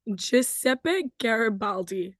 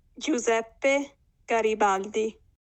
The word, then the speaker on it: Giuseppe Garibaldi: You pronounce his name Giuseppe Garibaldi. – Giuseppe Garibaldi.